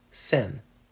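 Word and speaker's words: cent: They're both pronounced sen. But French Canadians like myself, we beg to differ. A penny is une sen, but a stage is une sine.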